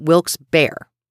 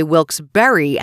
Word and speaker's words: Wilkes-Barre: The first thing I need to tell you about this town is you may hear the name pronounced differently. Some people, actually most locals I know, pronounce it Wilkes-Barre. Some people say Wilkes-Barre, and that's a no-no. Forgive me to those of you whom pronounce it that way, but you need to change that up.